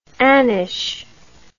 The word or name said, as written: annish